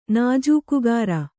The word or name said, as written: ನಾಜೂಕುಗಾರ